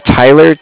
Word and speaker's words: Tyler: My name is Tyler, T-Y-L-E-R. And like I said, I'm calling with Cardiff. It's spelled C-A-R-D-I-F-F, as in Frank.